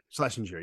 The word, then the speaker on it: Schlesinger: And then I always butcher the bass player, Adam Schisling or something like that. – Schlesinger, yeah.